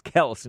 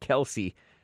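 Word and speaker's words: Kelce: It's Kels, not Kelsey. But you can call him Kelsey. That's what the dad turned all beta and acquiesced to that pronunciation. Brothers and NFL stars Jason and Travis Kels signed a lucrative deal with Amazon's podcast studio Wondery.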